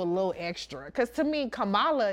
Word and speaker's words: Kamala: That's how you say her name. It's Kamala. I mean, to me, it rolls off a little easier.